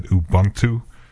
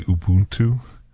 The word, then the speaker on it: Ubuntu: Yeah, so anyway, it's kind of interesting that Ubuntu, I've been saying that wrong all these years. I always said Ubuntu. It's actually Ubuntu.